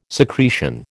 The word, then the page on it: secretion: American English